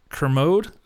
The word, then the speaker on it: Kermode: If I can throw my favorite critic into the ring, I'd highly recommend Mark Kermode. Oh, I hope I pronounced that properly. – You got it.